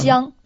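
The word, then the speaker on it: Jiang (姜): Jiang.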